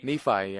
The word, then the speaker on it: Nephi: Nephi, yeah.